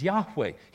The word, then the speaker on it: Yahweh: But because they don't have a J or a V in their language, it can't be pronounced Jehovah by the Hebrews. They couldn't say it literally. His name is Yahweh, and I take umbrage with the fact that in verse 12 it's translated Lord, which is a title.